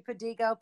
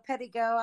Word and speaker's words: Pedigo: Yep, everybody calls me Pedigo. Pedigo, I like the Pedigo, though it sounds very European, which my last name actually is European. It's French.